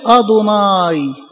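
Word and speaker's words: ادوناي (אֲדֹנָי): وبقوا ينطقوا كلمه ادوناي ادوناي يه مشدد، وبقوا اليهود ماشيين بالنظام دوت، يكتب يه ه واو ته مربوطه اربع حروف غير مشكله حروف ساكنه، ولما ينطقها ينطقها ادوناي. حلو لغايه كده، حلو لغايه القرن ال17 جه الملك جيمس حب يطبع الكتاب المقدس انجليزي، الكلام ده في سنه 1611، فجاه عند كلمه يهوى مكتوبه اربع حروف ساكنه وبينطقوها ادوناي، ا ممدوده دو عليها ضمه وهكذا،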